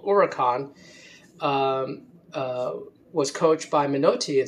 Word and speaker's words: Huracán: It starts with an H, but the H is silent in Spanish. So you could say it's Huracan, which would be Spanish for hurricane.